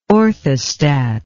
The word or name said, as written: orthostat